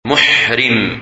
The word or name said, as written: Muhrim